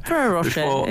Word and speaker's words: Ferrero Rocher: Ferrero Rocher, Ferrero Rocher before Halloween.